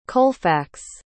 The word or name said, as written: Colfax